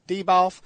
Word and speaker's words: Benioff: And it's worth noting too that I was reading an interview with Weiss and Deboff. – Benioff? – Yeah, Benioff, whatever.